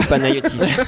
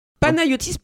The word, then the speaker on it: Panayotis: Panayotis Pascot, natif de Neuilly-sur-Seine. – Alors, par contre, le S de Panayotis se prononce pas. – Il est muet.